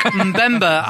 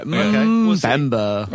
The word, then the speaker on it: Mbemba: How do you pronounce his name? – No. I think Mbemba, I'm pretty confident of that. – Yeah. Mbemba.